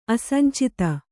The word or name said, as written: ಅಸಂಚಿತ